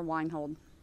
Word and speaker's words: Wienhold: Deborah Weinhold. – Thank you. – Spell your last name for me, Deb. W-I-E-N-H-O-L-D.